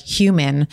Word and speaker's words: HVMN: And HVMN, I used to always pronounce it like human, but it stands for Health Via Modern Nutrition.